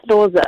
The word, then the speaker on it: Loser: It's Loser.